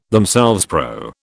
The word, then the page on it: themselves: British English